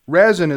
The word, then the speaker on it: resin: I said rosin, but that's incorrect. Resin is the way Ed says it. It's spelled R-E-S-I-N.